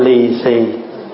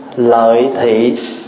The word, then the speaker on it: lì xì: In Vietnamese, we call lì xì. Lì xì is a come from a Chinese word, lì xì. Chinese Vietnamese pronunciation lợi thị, but the way we supposed to say lợi thị, but we used to call by Chinese word lì xì.